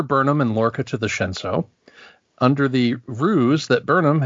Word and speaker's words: Shenzhou: Here, Burnham is the former captain of the ISS Shenzhou. Did I say that right? – You did. – Shenzhou.